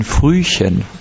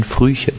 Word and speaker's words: Frühchen: This child was called ein Frühchen. This is how you say it in German language. Ein Frühchen.